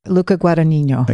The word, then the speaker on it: Luca Guadagnino: Luca Guadagnino.